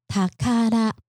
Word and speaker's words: 宝: 好，那下一个单字，第二个单字呢是写成宝藏的宝，那当然它的那个字体不是我们繁体字的那个字体哈，念作塔卡拉，它是宝藏、宝物的意思。